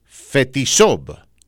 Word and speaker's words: Fetisov: usted tiene el apellido de Fetisov, en español le decimos Fetisov.